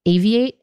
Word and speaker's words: Aviate: It was called Aviate. Am I saying it correctly? – You got it.